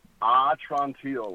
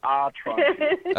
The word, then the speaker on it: Atrantil: So that's Atrantil, like, my belly's better. Atrantil.